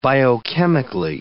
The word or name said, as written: biochemically